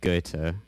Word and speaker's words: Goethe: I pronounce it Goethe.